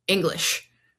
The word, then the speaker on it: English: This reminded me of Danny's recent discussion online about the word English and why it's spelled with an E instead of, say, an I. – I think it's the only example of an ENG making an ing sound.